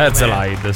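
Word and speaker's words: Herzeleid: Erzlide, dovrebbe essere questo. – Erzlide, sì. – Io ho detto bene. – Sì, sì, benissimo.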